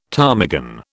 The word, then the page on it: ptarmigan: British English